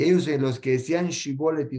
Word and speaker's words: Shibolet: ellos no pueden pronunciar la Shin, la, pronunciaban como Sin. Entonces le decían, dame, les decían una palabra, los probaban como, le decían, dime la palabra Shibolet.